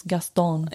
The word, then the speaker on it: Gaston: It's Gaston.